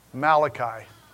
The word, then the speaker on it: Malachi: By the way, my messenger in Hebrew is pronounced Malachi.